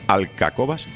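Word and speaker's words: Alcáçovas: siete años antes del descubrimiento de América, se firma un tratado llamado el de Alcacobas, el nombre es difícil de pronunciar porque es etrújula la palabra, Alcacobas,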